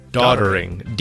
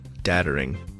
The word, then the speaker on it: doddering: Not daddering, doddering.